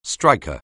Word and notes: striker: anglais

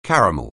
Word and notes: caramel: anglais

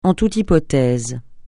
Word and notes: en toute hypothèse: français

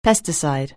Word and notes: pesticide: anglais

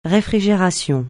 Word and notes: réfrigération: francais

